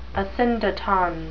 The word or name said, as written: asyndeton